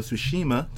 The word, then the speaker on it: Tsushima: First of all, it's called the Ghost of Tsushima. – Oh, there you go. – Okay. Oh, man. You and King of Hearts are so bad at pronouncing these Japanese names.